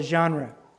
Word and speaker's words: genre: It's called a genre. The genres of Scripture, Z-H is the pronunciation.